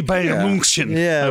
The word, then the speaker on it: Bayern München: Isn't that how you pronounce it? Bayern Munich? – FC Bayern München, I believe.